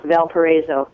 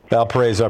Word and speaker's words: Valparaiso: I grew up in Arizona, so I'm here in the Vale of Paradise, and it's pronounced Valparaiso. – Valparaiso, I appreciate it.